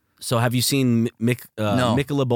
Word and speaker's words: Michelob: I laughed because you said Michael. – And then like a male's name. – You weren't confident in your own pronunciation. – It's Michelob. But you said Michael.